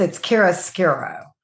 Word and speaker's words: chiaroscuro: I don't remember ever running into the term, and I may botch the pronunciation of it, chiaroscuro? – It's chiaroscuro. – Okay, that. – Yeah, I know. Believe me, I botched it too. But yeah, it's chiaroscuro. Yeah.